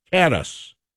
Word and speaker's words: Kattus: Tight end Josh Kattus, I believe is the way you pronounce his name, K-A-T-T-U-S.